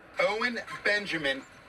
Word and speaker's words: Owen Benjamin: Owen Benjamin.